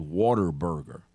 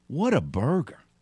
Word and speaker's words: Whataburger: This is how you pronounce the name of that establishment. What a burger. If you don't say What a Burger, it's like that's what it is. It's What a Burger. It's not Water Burger.